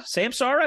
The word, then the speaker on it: Samsara: Samsara? Is that how you pronounce their name? – Yeah, there's some good friends.